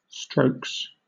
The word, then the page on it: strokes: British English